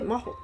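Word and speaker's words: 真秀: 誠の、まあ、真実の真に、えー、秀吉の秀ですねこれで真帆って読むんだって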